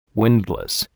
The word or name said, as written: windlass